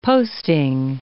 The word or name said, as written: posting